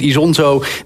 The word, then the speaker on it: Isonzo: Nee, het is Isonzo, de game die zich in de Eerste Wereldoorlog afspeelt. Zo zeg ik het goed, toch, Jos? – Ja, klopt.